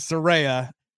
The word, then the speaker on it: Saraya: Thankfully, she clarified that it is Saraya.